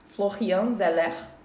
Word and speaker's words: Florian Zeller: O filme é em inglês, mas estamos falando de um diretor chamado Florian Zeller. Só que ele é francês, o que me faz mudar a pronúncia para Florian Zeller, poderia ser.